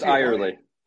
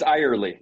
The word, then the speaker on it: Eyerly: It's Eyerly. – What's that? – It's Eyerly, yeah. – Eyerly. I was going to say Everly, then I'm like, wait a minute, that's a Y, not a B.